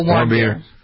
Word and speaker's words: Warmbier: Warmbier? – Otto Warmbier.